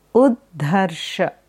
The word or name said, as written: Uddharsha